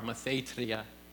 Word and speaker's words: mathetria: The second one that's there is mathetria. Got to say, roll your R, mathetria.